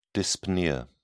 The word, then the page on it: dyspnea: British English